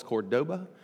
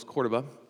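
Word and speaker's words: Córdoba: If you're from Memphis, you call this Cordoba, but it's pronounced by the locals Cordoba.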